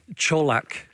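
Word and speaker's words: Čolak: How are we going to pronounce it? – It's definitely Colak. – Colak, okay. – Yes, and that's coming from good sources in Croatia.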